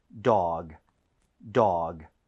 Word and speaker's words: dog: 자, 그리고 발음할 때 너무 duck 하고 끝내지 마세요. 이거는 네. – dog이기 때문에 g로 끝납니다. 부드럽게 하셔야 돼요. – 네. – 자, 한번 해볼게요. dog, dog.